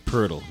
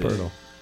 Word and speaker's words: Poeltl: Poeltl. That's how he's pronounced. – Poeltl.